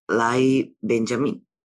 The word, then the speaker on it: Lyle Benjamin: His name is Lyle Benjamin.